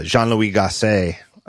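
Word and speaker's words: Jean-Louis Gassée: Jean-Louis Gasset, and forgive me if I butchered.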